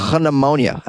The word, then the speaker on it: pneumonia: Pneumonia. I mean pneumonia. For some reason, they spell it with a P.